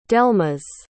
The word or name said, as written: delmas